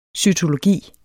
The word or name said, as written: cytologi